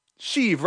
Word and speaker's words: Sheev: It's Sheev. Sheev rhymes with Steve.